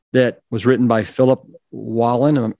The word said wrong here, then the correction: Wolin: Am I pronouncing that correct? – I think he says Wolin.